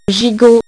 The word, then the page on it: gigot: French